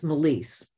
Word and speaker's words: Melisse: And I didn't, it's Melisse, but you spell it M-E-L-I-S-S-E.